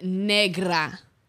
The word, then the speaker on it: negra: No, what is actually pronounced negra. Okay. The R is rolled.